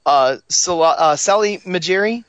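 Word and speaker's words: Salah Mejri: Salai Majeri? Did I say his name right? – Yep.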